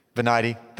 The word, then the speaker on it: Venite: I'm not good at saying Latin. Venite? Is that how you say it? Venite. Thank you for correcting me.